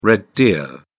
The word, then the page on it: red deer: British English